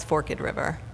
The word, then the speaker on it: Forked River: And then, you know, it's actually the locals are like, no, it's Forked River.